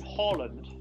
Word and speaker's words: Haaland: And got off to a great start with Erling Haaland.